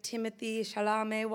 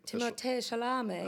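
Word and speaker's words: Timothée Chalamet: When Timothee Chalamet walked into the room, why do you say it like that? – He's French, and that's the right way to say it.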